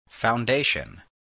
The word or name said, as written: foundation